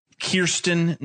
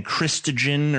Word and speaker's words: Kirstjen: But curious timing, Halberstam, because you and I roasted the shit out of what I now believe is pronounced Kirstjen Nielsen. Finally, after she's gone, I get her name right for once. I've been saying Kristjen or Kristjen because I thought it was just a Nordicized version of the name Christian.